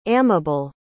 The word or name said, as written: amable